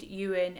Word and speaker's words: Ewen: And mine is at Jade Ewen, M-U-A. People always spell my surname wrong. It's E-W-E-N. Jade Ewen, M-U-A.